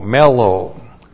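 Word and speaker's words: mellow (μέλλω): and then mellow, M-E-L-L, long O, mellow.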